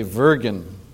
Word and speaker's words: würgen: The word worry is from the German word, I guess you'd say Würgen. Usually W is pronounced like a V in German, which means to choke.